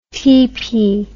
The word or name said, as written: teepee